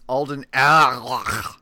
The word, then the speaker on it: Alden Ehrenreich: It's Alden Ehrenreich.